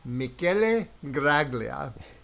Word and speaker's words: Michele Graglia: And today's guest is Michele Graglia.